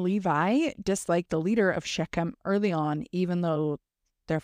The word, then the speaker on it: Shechem: From what I know about Hebrew and Arabic, that would probably be a k sound. – Okay. So Shechem.